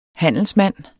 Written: handelsmand

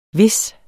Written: hvis

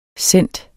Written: cent

